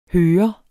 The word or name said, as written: hører